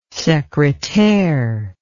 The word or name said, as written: secretaire